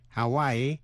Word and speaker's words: Hawaii: Tiểu bang Hawaii, Hawaii phát âm làm ba vần, Hawaii.